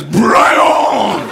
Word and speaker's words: Brian: Yes, my name is Brian. Very hard to say my name correctly, because my name is Brian. Can you say that? Very few can.